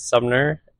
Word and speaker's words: Sumner: Yeah, my name is Sumner, S-U-M-N-E-R.